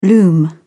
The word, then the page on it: loom: British English